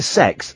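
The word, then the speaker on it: CeX: Then we went to Sex. That's how you pronounce computer exchange.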